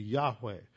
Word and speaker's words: Yahweh: That last syllable is the first syllable in the name of God. The second seems to be pronounced like weh, so it'd be Yahweh.